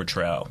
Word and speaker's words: Trow: do I pronounce his last name Tro or Trow? – Tro. I do.